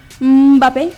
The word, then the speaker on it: Mbappé: Y bueno, el primer gol llegó al minuto 60 cuando Kylian Mbappé, porque él nos dijo que así se pronuncia su nombre. Mbappé. Es que luego le decimos Mbappé, pero sin la E. Sería Mbappé. Apareció con acento en la E.